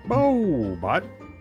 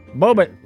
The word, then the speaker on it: Bohbot: Not, it must rhyme with robot. But he went. – He has like Bohobot or something. – B-O-H-B-O-T. – Bobot. – Bobot. Rhymes with robot.